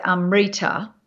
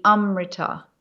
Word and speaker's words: amrita: But in Sanskrit, it would be amrita, amrita. See how I'm putting the stress on the first one? – Yes, uh-huh.